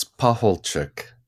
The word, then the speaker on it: Pacholczyk: And today we are joined by Father Tad Pahulcicek. Is that right, Father? – It's close. It's Pahulcik. – Pahulcik. – Yes. Okay. Chick like a chicken at the end.